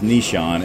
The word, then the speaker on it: Nyshaun: By the way, it's spelled N-Y-S-H-A-U-N. – Yes. – Right? But it's pronounced Nishan, and our last name is Harvey, and the business is Latte Lounge and HG Eatery.